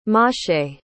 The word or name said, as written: mosheh